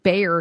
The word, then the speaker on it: Bayer: It's a German company. They're soaring. We pronounce it Bayer here.